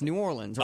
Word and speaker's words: New Orleans: I'm like, that's not how you say it. It's New Orleans.